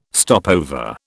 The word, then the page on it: stopover: British English